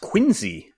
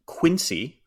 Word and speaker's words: Quincy: Quincy, Mass, pronounced Quincy, while other cities named Quincy are more pronounced like I just said it, like Quincy, Illinois, Quincy versus Quincy.